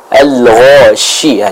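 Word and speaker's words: al-ghashiyah (الغاشية): Contoh, hal ataka hadithul ghashiyah, al-ghashiyah, jelas shi, bukan si, tapi shi, terdengar dengan jelas suara dari huruf shin tersebut.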